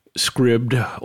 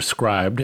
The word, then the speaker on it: Scribd: And then I used a service that we've talked about before called Scribd or Scribd, S-C-R-I-B-D, which I've heard people pronounce as Scribd, but I can't stop myself from calling it Scribd,